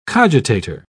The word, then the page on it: cogitator: British English